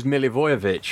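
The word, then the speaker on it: Milivojević: Milijovic. – I was trying to get all of the I's in there. – I thought it was Milivojevic.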